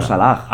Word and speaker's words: Salah: Pero por ejemplo en árabe todas las H se pronuncian. Claro. O sea, es como Azahara. Yo digo Salah.